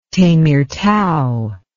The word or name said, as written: Temirtau